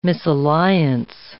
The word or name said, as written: misalliance